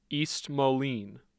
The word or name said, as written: East Moline